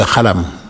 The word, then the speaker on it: xalam: Et en wolof, tu prends un exemple, un groupe de musique qui s'appelle le « khalam », qui s'écrit « X-A-L-A-M ». Donc au Sénégal, on dit le « khalam ».